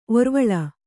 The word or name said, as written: ಒರ್ವುಳಿ